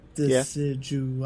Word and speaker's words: Decidueye: I think it's because I have to think how to say Decidueye by saying it properly. Because I'm like, Decidueye. Yeah. Four syllables, man. It gets kind of, that gets a little hard.